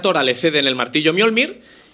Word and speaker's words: Mjolnir: Estoy diciendo Mjolnir, intentando pronunciarlo bien. Yo he dicho Mjolnir de toda la vida, pero creo que como con la I está bien dicho.